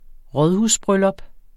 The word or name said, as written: rådhusbryllup